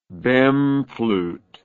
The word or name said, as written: bohm flute